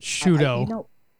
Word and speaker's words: Sciutto: How do you say his name? – Sciutto. – Sciutto. It is Sciutto.